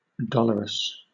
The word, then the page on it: dolorous: British English